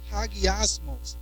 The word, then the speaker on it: hagiasmos: And the word holy comes from a Greek word that's actually pronounced like this, hagiasmos.